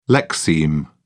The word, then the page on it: lexeme: British English